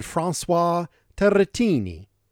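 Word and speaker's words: Turrettini: Francis Turretin, that's how we English speakers pronounce his name, but it is better said François Turretini.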